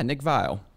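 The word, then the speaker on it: Viall: But the naughtiest is Nick Valley. Is that how I pronounce his last name? – Yeah, Nick Vile.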